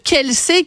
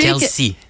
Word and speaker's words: Kelce: C'est Kelsey, Kelsey. – Kelsey. – Kelsey. – C'est ce qu'on me dit, en tout cas.